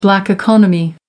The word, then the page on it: black economy: American English